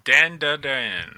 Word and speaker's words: Dandadan: It is Dandadan.